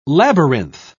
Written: labyrinth

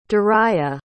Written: durriyah

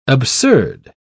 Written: absurd

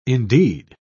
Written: indeed

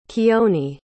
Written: kioni

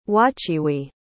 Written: wachiwi

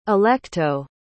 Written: alecto

